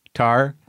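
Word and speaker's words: Tár: So, I watched this movie, Tar.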